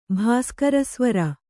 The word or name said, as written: ಭಾಸ್ಕರಸ್ವರ